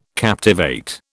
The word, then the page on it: captivate: British English